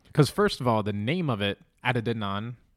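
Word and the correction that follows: Adidon: I don't know if I'm saying it right. – Adedon.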